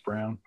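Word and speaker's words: Braun: Christian Brown. LeBron. Pronounced Brown.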